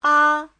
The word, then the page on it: 啊: a5